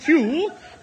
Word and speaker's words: Pugh: N-E-D-D-I-E. Neddie? What was next? – Neddie Pugh Seagoon. – Pugh? P-H-E-W? – No. It's pronounced Pugh, but it's spelt Pug. – Pug, yes. P-U-G-H.